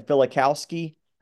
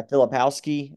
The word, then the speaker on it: Filipowski: Filipowski. – Yeah, Filipowski.